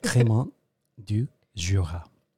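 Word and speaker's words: Crémant du Jura: Cremant du Jura.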